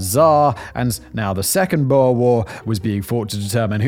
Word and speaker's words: Boer War: It kicked off the First Boer War when the British then annexed.